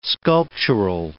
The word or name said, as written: sculptural